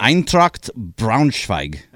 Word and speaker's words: Eintracht Braunschweig: Schroeder is a fan of a soccer club in Germany called Eintracht Braunschweig. Sure. I think I got it right. – Say it again. – Yeah, that sounds right. – Eintracht Braunschweig.